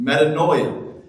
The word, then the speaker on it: metanoia: The main word in Scripture that we see translated repent is the Greek word metanoia. That will not be on the test, but just so that you know that I know how to pronounce it, we've said it, metanoia.